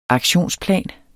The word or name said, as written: aktionsplan